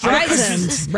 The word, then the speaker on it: Streisand: Streisand, right? – Yes. – Sand, yeah, with the S, like, sand. – Yeah. – Yes. – Streisand.